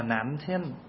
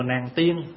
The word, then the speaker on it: Nam Thiên: Hồi nãy mình đi vào chùa Nam Thiên á, chùa đó tên là chùa Nam Thiên, mà người tiếng Đài Loan người ta phát âm là Nam Thiên chứ không phải là chùa Nàng Tiên.